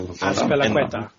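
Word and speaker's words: Azpilicueta: You know, he took a half chance and then he sat down he sat down Mendy and Aspilicueta. I might have butchered that. – Aspilicueta, yeah.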